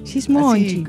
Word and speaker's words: Gismonti: Esberto Gismonti sería, o Gismonti. – Gismonti. – Así, con onda, brasilero, sí.